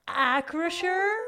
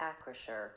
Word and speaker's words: Acrisure: And wait, there's already on the first syllable. Ack. – What the hell is that? – Acrisure. – They have the Acrisure Stadium. – The final syllable sounds like the word sure.